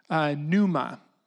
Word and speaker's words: pneuma: Pneuma is kind of how you pronounce it.